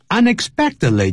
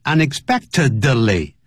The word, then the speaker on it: unexpectedly: 感冒尤其是流感呢是可以传染的。Contagious, C-O-N-T-A-G-I-O-U-S. 所以这个是要特别特别记住。好 ,unexpectedly 就是意想不到的。I found a secret about Dad unexpectedly. 不要念成 unexpectedly. Unexpected, 那个 D, 停顿。Unexpectedly, 就像 friendly. He's very friendly with us.